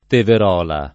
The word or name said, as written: Teverola